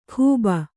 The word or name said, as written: ಖೂಬ